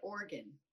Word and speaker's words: Oregon: How do you say the state in which we are talking about? – I've always said Oregon. – Of course you have, because that is correct.